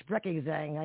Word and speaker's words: Sprechgesang: Oh, it's a German term, Sprechgesang. – Yeah, obviously, yeah, Sprechgesang.